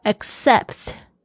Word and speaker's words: accept: 然后 when you accept yourself，accept，大家特别注意一下，它有两个 c 的发音都是不一样，一个是 k，一个是 s，还有 accept 就是接受。